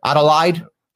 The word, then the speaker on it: Adelaide: Did I say that right? Adelaide, Australia. Yeah. Yeah. I feel like that was convincing.